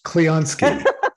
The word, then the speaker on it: Clionsky: Close, Clionsky.